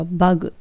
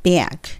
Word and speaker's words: bag: ここはしっかりとエアを効かさないと、虫のバグにも聞こえちゃうので、普通に言うとバグって言っちゃうと、 U の発音なので、 B、 A、 G はベアグ。